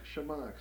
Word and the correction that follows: Chemox: That's Chemox. Chemox. I think is a proper pronunciation. We call it Chemox.